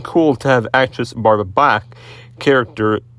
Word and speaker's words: Bach: Barbara Bach, like Bach the famous musician.